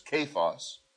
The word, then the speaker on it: Cephas: And he was seen of Cephas," you know, Cephas, Cephas, that's the correct pronunciation, which is Peter, "and of the 12, that is the 12 apostles.